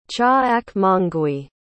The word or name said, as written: Cha'akmongwi